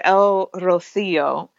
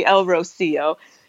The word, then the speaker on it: El Rocío: And there is a pilgrimage called El Rocío. Sorry, I have a Spanish accent. So yeah, you say it right. – People make fun of me, but you could say El Rocío, which is a small town in the south of Spain.